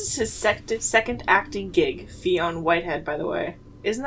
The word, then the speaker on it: Fionn: That follows, yeah, Tommy, played by Fionn Whitehead. That's the only way I can pronounce it. – I think that's pretty accurate. Fionn.